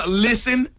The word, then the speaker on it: listen: Listen is another one where the English words has a silent letter. – Silent and listen? – T. Listen. You ain't no T in listen.